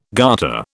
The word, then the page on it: garter: British English